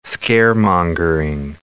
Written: scaremongering

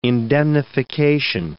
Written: indemnification

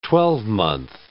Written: twelvemonth

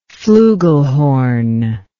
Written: fluegelhornist

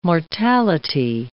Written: mortality

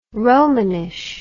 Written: Romanish

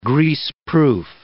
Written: greaseproof

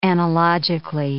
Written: analogically

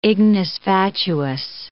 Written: ignis fatuus

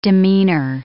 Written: demeanor

